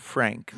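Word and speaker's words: Phranc: It was a woman from Southern California, a folk singer by the name of Frank, with a PH and a hard C.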